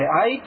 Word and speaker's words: IEEE: それぞれの特徴を理解すると、それから同じこと言ってんね、あと規格の種類と周波数帯と最大伝送速度って後ろで言ってる 3つの並びが、これが通信規格の IEEE って書いて IEEE と読みます。